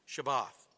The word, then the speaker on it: Shabbat: And the reason you see on the screen here, the reason you only see three letters is because there are no vowels in Hebrew. But those three letters are pronounced Shabbat.